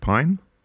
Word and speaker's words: Pyne: Shout out to Patrick Pyne, P-Y-N-E. How would you pronounce that? Pyne, yeah. – Pyne, Patrick Pyne, in Seattle.